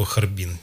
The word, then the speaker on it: Харбин: Я почему-то называю его Харбин. Не знаю, прости. – Не знаю, мне как-то... Его так и так, можно ударять по-настоящему. Есть две традиции ударения.